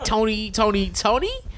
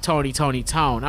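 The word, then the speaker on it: Tony! Toni! Toné!: I was like, Tony Tony Tony. You was like. – No, dog. It's pronounced Tony Tony Tone.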